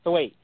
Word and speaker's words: Thwaites: Thwaites. – Okay, so Brenton Thwaites will be playing Dick Grayson, a.k.a.